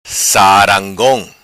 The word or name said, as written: Sarangong